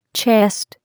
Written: chest

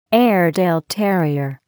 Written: Airedale terrier

